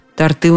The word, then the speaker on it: торты: Торты у нас всегда свежие, с гордостью говорит мне милая девушка в кондитерской на углу. А запомнить-то очень просто. Ударение никуда не уходит с корня торт.